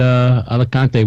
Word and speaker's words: Alicante: Yeah, no, and I guess that adds to year-round flights now that you can get from Norwich to Malaga and Alicante, which I'm sure I mispronounced completely.